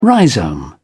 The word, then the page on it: rhizome: British English